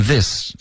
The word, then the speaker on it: this: Regret，跟 this 这个应该要咬舌尖。